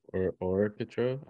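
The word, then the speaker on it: Orketro: What do you guys think of his new Adidas Orquitero? What the? Orquitero? What the hell's the name of that shoe? – Orquitero? I think you said it right.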